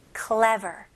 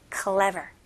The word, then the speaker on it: clever: 네, 발음은요. 여기서 cle, cle 그다음에 v 발음으로 ver. ver. 여기서는 v 발음 ver 이렇게 윗니 밑에 입술. ver 그다음에 r로 마무리하셔야 돼요. clever. clever. Okay.